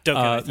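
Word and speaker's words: Dokken: Yeah, Doken.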